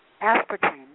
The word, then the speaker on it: aspartame: Aspartame, yes. – Aspartame. – You're pronouncing it correctly.